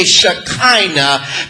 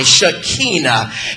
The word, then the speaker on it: Shekinah: We often say Shekinah, but it's actually a long I, Shekinah.